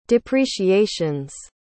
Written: depreciations

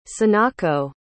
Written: sanako

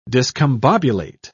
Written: discombobulate

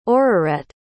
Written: aurorette

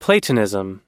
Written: platonism

